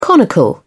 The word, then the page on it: conical: British English